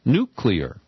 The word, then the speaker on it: nuclear: the correct pronunciation is nuclear. To me, it's a very easy word. There is no trick syllable in the middle. There's no Y in nuclear.